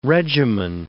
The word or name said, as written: regimen